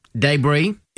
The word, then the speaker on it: debris: Five words with hidden S words, silent S words. Isle. – Yes. – Island. – Yeah. – Debris. – Yeah. – Apropos. – Oh, nice. – And one I think you really like, bourgeois.